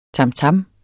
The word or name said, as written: tamtam